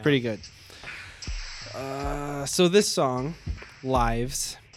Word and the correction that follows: Levi's: It's Levi's. It's actually it's them being corporate capitalist sellouts even more. It's Levi's is actually how it's pronounced.